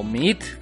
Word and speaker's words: Meade: Y por otro lado, está Meade, o Meat, porque ya saben, gasta un chingo para que digan Meat, soy Meat, me llamo Pepe Meat, díganme Pepe, pero mi nombre, mi apellido sí pronunciado como lo deben pronunciar en Irlanda, ¿no?